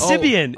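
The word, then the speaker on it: Sybian: Sabian. – You mispronounced it too. It's Sibian.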